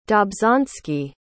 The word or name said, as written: dobzhansky